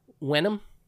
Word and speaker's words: Wenham: How do you spell that? W-E-N-H-A-M. – Wenham. That sounds about right, yeah.